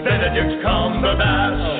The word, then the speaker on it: Benedict Cumberbatch: Benedict Cumberbatch.